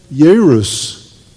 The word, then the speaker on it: Jairus: And this man, Jairus, is probably how it's pronounced. Aramaic nor Hebrew have a J sound. It'd be Jairus.